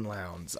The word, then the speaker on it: Lowndes: It rhymes. Human misery abounds in Lowndes.